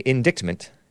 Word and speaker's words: indictment: Federal law prohibits people under felony indictment is how it's really pronounced. I have learned, even though it's spelled indictment, from buying guns.